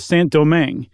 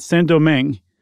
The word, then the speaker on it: Saint Domingue: I read Chuck that Saint Domingue, um, and I looked it up. I'm pretty sure that's how you say it.